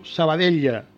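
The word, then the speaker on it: Sabadell: Rubén Martínez pasa del césped del despacho al Club Esportiu Sabadella, que la L también se pronuncia, Club Esportiu Sabadella.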